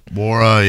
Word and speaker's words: Nwora: I'm assuming it's Jordan Wara, right? – Yeah. – I mean, I'm assuming Nora, it's a silent N. – Wara, yeah.